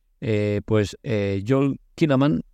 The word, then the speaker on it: Joel Kinnaman: Joel Kinnaman, lo he dicho mal antes, perdón, es el protagonista y aquí pues lo veo muy, muy pasado de vueltas.